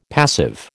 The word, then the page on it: passive: American English